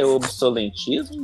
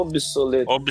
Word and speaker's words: obsolescência: Eu tenho uma pergunta, a pronúncia é obsolentismo ou obsolescência. O que que eu falei? – Você falou certo.